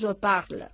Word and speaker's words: je parle: Entonces, el presente es je parle, acabado en E, pero que no se pronuncia.